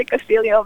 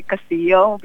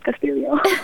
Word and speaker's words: Castillo: It always makes me happy when I hear someone say Castillo, because most people say Castillo, because that's the Spanish way of pronouncing it. And I always want to say, oh, no, but Filipinos pronounce it Castillo.